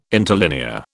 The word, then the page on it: interlinear: British English